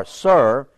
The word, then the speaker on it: Sur: unto Sur, S-I-R, Sur, that is before Egypt, as thou goest towards Assyria, and he died in the presence of all his brethren.